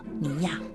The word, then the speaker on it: 圆仔: 后来，人们投票为他选了一个可爱的名字，圆仔，就是小汤圆的意思，闽南语读作尼亚。